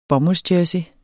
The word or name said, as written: bomuldsjersey